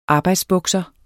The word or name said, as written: arbejdsbukser